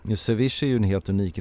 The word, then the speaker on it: ceviche: ceviche. – Precis, det är så man uttalar det, ceviche.